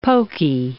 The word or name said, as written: poky